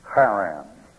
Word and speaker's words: Haran: In the Old Testament, it's spelled H-A-R-A-N. Charan. That's that rough H in Hebrew you fellows have been studying.